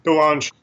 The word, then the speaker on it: DeLonge: Tom DeLonge, I don't know if I always say it right. – Yeah, DeLonge.